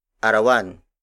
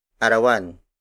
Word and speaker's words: arawan: For example, arawan, arawan, arawan. Arawan is spelled as A R A W A N.